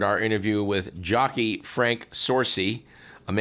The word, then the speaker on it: Sorci: S O R C I. Rhymes with horsey.